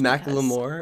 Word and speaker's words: Macklemore: But anyway, the reason I get so annoyed by this is just Macklemore. Because... Is that how you say it? – Yeah.